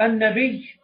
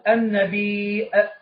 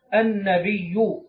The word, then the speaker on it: النبي: هل يقال النبي هكذا بالياء المشددة في آخرها، أو يقال النبي بهمزة بعد الياء؟ يقول: والأكثر في التلفظ به عدم الهمز. إذن أكثر العلماء لا يهمزون كلمة النبي، وإنما ينطقونها هكذا كما نطقت بها: النبي، النبي، النبي، ولا يضعون همزة في آخرها.